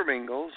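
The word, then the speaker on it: Mingils: So for those people unfamiliar with my voice, it's Peter Mingles,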